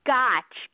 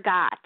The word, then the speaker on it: Gotsch: Gotch like gotcha. That's gotcha. – Like a gotcha.